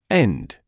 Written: end

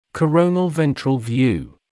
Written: coronal/ventral view

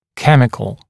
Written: chemical

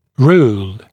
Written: rule

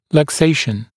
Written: luxation

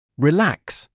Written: relax

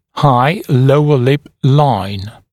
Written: high lower lip line

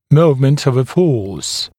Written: moment of a force

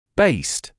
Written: based

